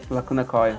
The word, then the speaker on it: Lacuna Coil: Lacuna Coil, né? Que pronuncia. – Lacuna Coil.